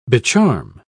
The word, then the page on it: becharm: British English